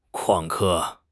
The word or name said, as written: 旷课